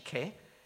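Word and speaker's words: ke (και): They read into the Greek word ke, because it says ke there. Or kai, if you're studying modern Greek and you the modern pronunciation, but it's actually ke.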